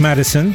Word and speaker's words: MDSN: Here's a band, MDSN, pronounced Madison. For some reason, it's capital M, capital D, capital S, capital N.